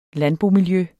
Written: landbomiljø

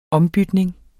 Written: ombytning

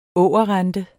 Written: ågerrente

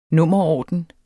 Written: nummerorden

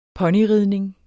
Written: ponyridning

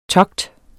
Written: togt

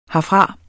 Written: herfra

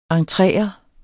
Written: Entreret

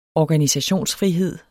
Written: organisationsfrihed

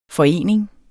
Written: forening

